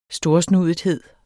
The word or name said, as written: storsnudethed